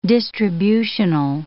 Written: distributional

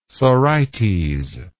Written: sorites

